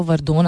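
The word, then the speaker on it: Verdun: वर्दोन था क्या वर्डन लिखते हैं इसको वर्दोन I think would be the सही प्रोनाउंसिएशन 21 फरवरी को um,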